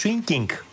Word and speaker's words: swinging: Δεν είναι swingy, είναι swinging.